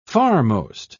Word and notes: farmost: British English

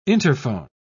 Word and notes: interphone: British English

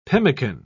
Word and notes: pemmican: British English

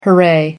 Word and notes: hurray: American English

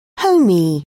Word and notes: homey: British English

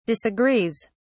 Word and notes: disagrees: British English

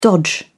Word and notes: dodge: British English

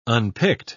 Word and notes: unpicked: British English

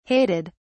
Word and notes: hated: British English

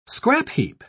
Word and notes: scrapheap: British English

